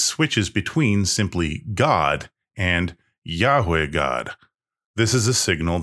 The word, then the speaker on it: Yahweh: However, God's personal name is a different word, pronounced something like Yahweh.